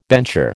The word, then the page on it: bencher: American English